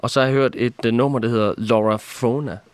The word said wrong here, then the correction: Laura Fora: Er det rigtigt udtalt? Næsten. Ja, Laura Fora.